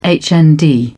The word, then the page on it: hnd: British English